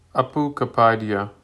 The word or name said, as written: Apu